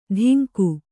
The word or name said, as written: ಧಿಂಗು